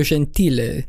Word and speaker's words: Gentile: So if you guys recall on last week, we had Claudio Gentile. – Yes. – Which is actually how you pronounce it. So oh, shit. – We were pronouncing it wrong the whole time.